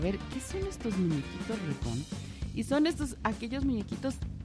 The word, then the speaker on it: Reborn: Reborn, así se pronuncia.